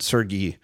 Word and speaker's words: Sergey: Oh, that's a hard G? – Sergey.